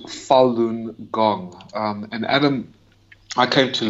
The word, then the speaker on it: Falun Gong: It's pronounced Falun Gong,